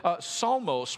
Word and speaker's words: psalmos: to a Greek term that psalmos, which also has a silent P at the beginning, and we don't know why.